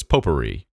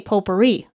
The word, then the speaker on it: potpourri: So it's potpourri. – Yeah, or, or uh, I like that. You you stress the first syllable. I say potpourri potpourri.